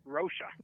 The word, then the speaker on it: Rocha: well, we have John Roca. Is that pronounced right? Rocha. Rocha. Sorry about that.